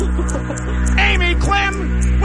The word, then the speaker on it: Amy Quim: So that's how you say it. Amy Quim, welcome to the payroll payout.